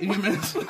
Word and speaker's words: Inhumans: Talking about superhumans, let's talk about inhumans. – Yes. – Is this right, inhumans? – Oh, yes, inhumans!